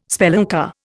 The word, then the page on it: spelunker: Indian